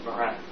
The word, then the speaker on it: Marin: We're in the town of Marin, M-A-R-I-N. – Very convenient. – M-A-R-I-N.